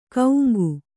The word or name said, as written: ಕೌಂಗು